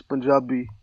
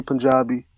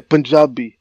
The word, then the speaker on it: Punjabi: In our language, which is Punjabi, people say Punjabi, whatever. – Right. – I want to say it right. – I want to say everything right. – Punjabi. Okay. Punjabi. All right. Yeah, that's how you say it.